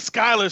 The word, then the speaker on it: Schuyler: The Schuler sisters. – The Schuyler sisters. – Schuyler sisters. Yeah, I knew it was that.